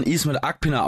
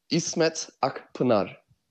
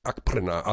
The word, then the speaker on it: Akpınar: Wir sprechen dich, Ismet, seit Jahren Ismet Akpina aus, aber wir haben irgendwann mal gelernt, dass das gar nicht die richtige Aussprache ist. Richtig für deinen Nachnamen? – İsmet Akpınar. – Ja, genau. Also wir haben einen türkischen Kollegen, der sagte, der heißt Akpınar.